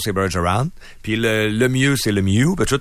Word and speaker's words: Lemieux: Le R, il l'avait moins, là, mais le mieux.